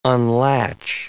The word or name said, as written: unlatch